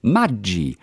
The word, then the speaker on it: Maggi: Dass das Produkt eigentlich italienisch ausgesprochen wird, also wie in Lago Maggiore, Maggi.